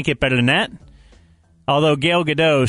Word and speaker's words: Gal Gadot: Anyway, so in Today in Twitter, we're going to look at Gal Gadot. I believe that's how you pronounce Wonder Woman's name.